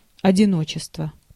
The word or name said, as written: одиночество